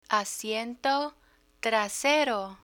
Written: asiento trasero

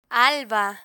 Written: alba

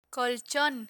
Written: colchon

